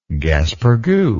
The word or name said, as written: gaspergou